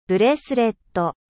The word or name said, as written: bracelet